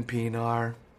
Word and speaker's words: Pienaar: Of course, I'm talking about Steven Pienaar. How we miss you. – Pienaar, Steven Pienaar. – What a player. What a player. – So guys, how would you describe Steven Pienaar's play to someone who's never seen him?